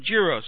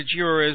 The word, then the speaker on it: gyro: Maybe they ran out on Saturday, because that was when the party was, because I went out on Sunday to get some gyros. So those of you who don't know what a gyro is, or a gyro, or however you want to pronounce it, G-Y-R-O, it's a Greek fast food thing.